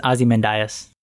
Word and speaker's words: Ozymandias: It has to be Ozymandias. Did I even say that right? – I think it's Ozymandias. – Ozymandias, right. Oh, it's Mr. Oz, Ozymandias.